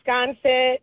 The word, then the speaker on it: Sconset: The Sconset Bluff Walk. – Yes, I do. It's one of my favorites. – Well, first of all, am I saying it correctly? – Yes, you are. Yep. Sconset.